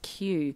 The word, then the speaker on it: Kyu: It's called Pixophony, I think, and it's by a band out of Sydney called, and you pronounce it Q, but it's actually spelt K-Y-U.